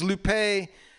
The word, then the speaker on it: Lupe: This is Lupe, L U P E, with the high uh, accent on the E.